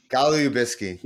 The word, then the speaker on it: Golubitsky: Did I say that right? – Golubitsky. Yeah.